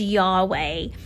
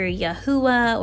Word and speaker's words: Yahweh: The way I pronounce the name, and I'm not saying that it's the correct way, but my way of that pronunciation and a common one is Yahweh. You may also hear Yahuwah or Yehovah, Jehovah. So a lot of different ways to pronounce that.